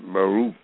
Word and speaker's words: Baruch: God has called President Barack Obama a Baruch, as his name is in the Jewish, in the Old Testament, in the Hebrew, it's pronounced Baruch, B-A-R-U-C-H.